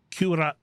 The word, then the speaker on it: Cuarenta: Oh man, I pronounced this right at home, and I just blew it here. E, that's the letter E, Cuarenta.